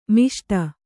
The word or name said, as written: ಮಾಸಟೆ